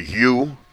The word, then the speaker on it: ewe: I'm sure many of you know, it's a ewe, spelled E-W-E, pronounced U.